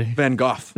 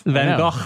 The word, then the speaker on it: Van Gogh: He pronounces Van Gogh correctly. – Van Gogh. – Van Gogh. – He did it.